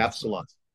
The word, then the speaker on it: Absolon: Is it Absolon or Absol? How was he? How's the band pronounced? I was right. All right, good. I had a feeling. – Absolon. Right. Like the movie.